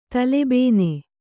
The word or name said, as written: ತಲೆಬೇನೆ